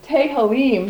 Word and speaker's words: Tehillim: The name of this album is Tehillim. That's how he pronounces it.